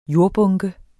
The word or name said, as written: JORDBUNKER